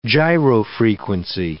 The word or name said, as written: gyrofrequency